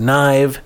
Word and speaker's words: Gnyve: In Knive, we spell it all weird because we're from the new metal generation, so it's spelled G-N-Y-V-E, pronounced Knive.